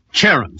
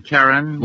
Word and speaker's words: Charon: I think it's Charon, isn't it? – Isn't it Charon? – Well, don't ask me.